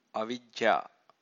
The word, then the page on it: avijja: Pali